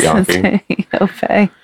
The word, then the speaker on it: Yoffe: It's Yoffe, but she pronounces it Yoffe. I don't know. It's Y-O-F-F-E.